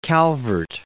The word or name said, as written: Calvert